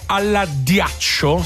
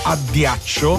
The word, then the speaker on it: addiaccio: Dormire alla ghiaccio, si dice addiaccio.